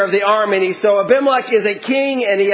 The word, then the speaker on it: Abimelech: At that time, Abimelech, and that is the correct way of pronouncing it.